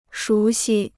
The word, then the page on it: 熟悉: shú xī